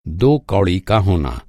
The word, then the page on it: कौड़ी: کوڑی